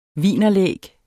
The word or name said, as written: wienerlæg